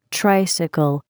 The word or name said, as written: tricycle